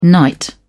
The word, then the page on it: knight: British English